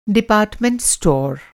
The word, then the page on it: डिपार्टमेंट-स्टोर: Hindi